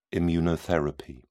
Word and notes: immunotherapy: British English

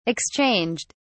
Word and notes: exchanged: British English

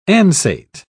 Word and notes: ansate: British English

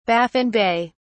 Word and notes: Baffin Bay: British English

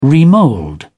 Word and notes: remould: British English

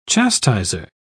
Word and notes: chastiser: British English

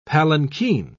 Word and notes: palankeen: British English

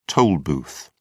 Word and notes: toll booth: British English

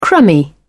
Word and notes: crummy: British English

